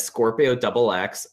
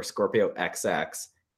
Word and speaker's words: Scorpio X2: It's spelled out Scorpio X2, but I've heard it as Scorpio Double X or Scorpio XX.